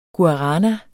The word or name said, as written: guarana